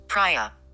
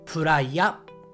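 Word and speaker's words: Praia: プライアはい、英語ですと、プライアはい、えー、日本語もこれは同じです。プライヤ、プライヤと発音します。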